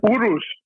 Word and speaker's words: Urus: Is that how you say it? – Urus. – Yes.